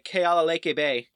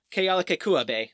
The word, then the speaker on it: Kealakekua Bay: And Cook just happened to show up in the Kealakekua Bay. Kealakekua Bay, sorry.